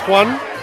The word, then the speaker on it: Juan: My name is Juan. It's spelled J-U-A-N.